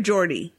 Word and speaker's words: Geordi: Is it Gordy or Geordi? – Geordi. – Geordi LaForge. Mm-hmm. So you want to pronounce her name with a soft G like germ, gentle, and exactly.